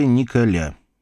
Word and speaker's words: Николя: Его звали Николя. Именно так на французской манер с ударением на последний слог он и предпочитал представляться людям, с ним ранее незнакомым.